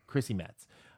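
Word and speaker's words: Chrissy Metz: Because Kate is, who's played by Christy Metz. Is that how you say it? – Yeah. – Christy Metz.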